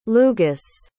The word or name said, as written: Lugus